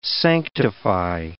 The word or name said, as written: sanctify